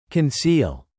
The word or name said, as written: conceal